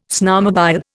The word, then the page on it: snowmobile: Indian